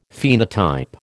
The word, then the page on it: phenotype: American English